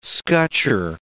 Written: scutcher

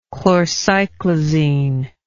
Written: chlorcyclizine

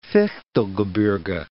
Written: Fichtelgebirge

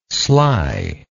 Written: schley

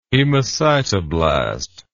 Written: hemocytoblast

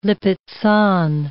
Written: Lippizan

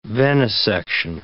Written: venesection

